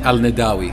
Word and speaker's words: Al-Nadawi: I said it wrong. Please correct me. Omar Al-Nadawi. – Al-Nadawi. I even wrote it down phonetically and I got it wrong.